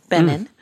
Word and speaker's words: Benincasa: It was with Sarah Benincasa, which I've learned to pronounce the N, Benin.